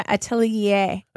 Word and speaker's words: Atelier: How do you say Atelier? I can't. The, the second word is so hard for me to Atelier. – Atelier. So Atelier. That, yeah. Am I saying? I am You're saying, saying it right. Perfectly.